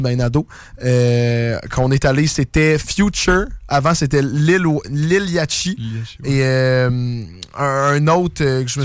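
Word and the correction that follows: Lil Yachty: Prononce pas le CH. Ah! – Le CH, Liliati? Liliati.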